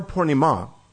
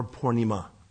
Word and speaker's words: Purnima: And in anticipation of Gaur Purnima, by the way, a lot of people say Gaur Purnim. Please get the A on the end there. It's a long A. Gaur Purnima, okay?